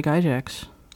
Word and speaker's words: Gygax: I believe it was started by Gary Gygax.